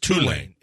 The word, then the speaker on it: Tulane: It's Tulane. It's not Tulane. It's Tulane. – Right. – The accent is on the U.